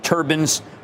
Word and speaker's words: Turbines: with Turbin's, which is the T-R, actually T-U-R-B-I-N-E-S, but they pronounce it like the thing that you wear, you know? – Yeah.